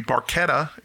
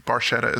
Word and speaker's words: Barchetta: It should be Barchetta is the correct pronunciation, okay. But Barchetta is how Getty pronounces it.